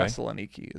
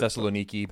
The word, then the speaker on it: Thessaloniki: Thessaloniki is probably the way the Greeks would pronounce it. – Thessaloniki, but we're probably going to say Thessalonica. – Because that's, you know.